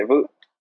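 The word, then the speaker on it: Vučević: That's how you say Vucevic.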